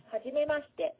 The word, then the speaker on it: はじめまして: Hajimemashite.